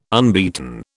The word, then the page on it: unbeaten: British English